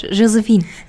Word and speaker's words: Josephine: Und außerdem wird mein Name französisch ausgesprochen, nicht deutsch. Josephine.